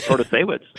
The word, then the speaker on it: Protasiewicz: Well, I think it has become a nationalized race because hosts of national podcasts can pronounce Protasiewicz.